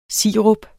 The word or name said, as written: sirup